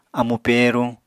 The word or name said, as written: amu pèeruñ